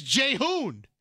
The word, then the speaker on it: Jae-hoon: For example, my full name is Jae-hoon. For non-Koreans, it's easier just to spell it J-A-E, which sounds like Jay in Korean. And it's not pronounced like Jay, though. But we really don't know how else to spell it. J-A-E is the closest English spelling to our Korean name.